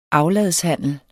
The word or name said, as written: afladshandel